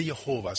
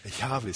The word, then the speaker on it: Yahweh: Inte Jehova som Jehovas vittnen säger, utan förmodligen Yahweh ska det uttalas.